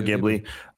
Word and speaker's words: Ghibli: Studio Ghibli, did I say that right, Tim? – Sounds right, yeah. – Okay, yeah, Studio Ghibli.